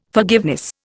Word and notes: forgiveness: Indian

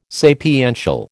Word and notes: sapiential: American English

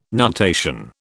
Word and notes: nutation: British English

